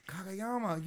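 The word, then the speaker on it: Kageyama: Kagayama, you were so close. Fuck. – Dude, okay, so I talked about this earlier. – It's a magic block. – I cannot say his name right because for me, every time I start, it goes to Kamigawa, which is the magic plane.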